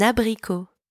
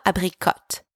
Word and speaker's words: abricot: Un abricot. Un apricot. Un abricot. Hey, quick pronunciation not here. It's not abricot. The T is silent. Pretend it's not even there. Abricot.